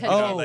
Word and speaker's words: Tatiana: You don't want to put the H in. – Tatiana. Like, no, or like Tatiana. You know, like, Tatiana.